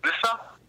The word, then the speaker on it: Lissa: Here, I'll spell it for you, okay? – Yeah. – L-I-S-S-A. – Lisa? Lissa? – Lissa. – Lissa. – Say it 16 times and you won't forget it.